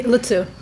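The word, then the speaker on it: Luzzu: Luzu? – Lutsu. – Lutsu. Okay, yeah. I mispronounced it.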